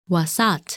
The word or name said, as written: Wasat